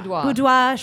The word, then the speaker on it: boudoir: So I, you know, just so you know, they're doing a live boudoir. How do you say that? – Boudoir shoot with La Perla and other fancy things.